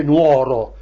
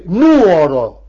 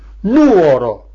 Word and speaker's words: Nuoro: Non si dice Nuoro, si dice nuoro, con l'accento sulla U, nuoro.